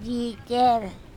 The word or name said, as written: ji cEr